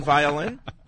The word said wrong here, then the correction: Volin: It rhymes with colon. – Volin. – You can say his name. – Yeah. – Volin rhymes with colon.